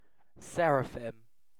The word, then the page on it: seraphim: British English